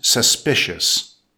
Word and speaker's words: suspicious: Emphasize the second syllable that's pronounced suspicious. Definition: behavior or an event that causes doubt or mistrust.